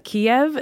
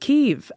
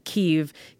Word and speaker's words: Kyiv: But the second thing that was really interesting to me about that is that Kiev is actually pronounced Kyiv. So apparently that's the Ukrainian pronunciation, Kyiv. Kiev is how Russians say it, and it's a holdover from the Soviet days.